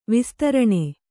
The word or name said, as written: ವಿಸ್ತಾರಣ